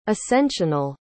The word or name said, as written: ascensional